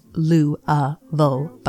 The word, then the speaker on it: Louisville: Louisville, and it is pronounced Lou-a-ville by locals, is a city steeped in history.